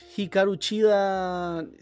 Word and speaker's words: Hikaru Chida: Después tenemos Hikaru Chida. – Perdón con las pronunciaciones, de repente me confundo con, el. Está bien pronunciado, amigo.